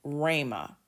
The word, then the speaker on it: rhema: And so again, we see here, there's another word study, Strong's Concordance 4487, and that's pronounced Rhema.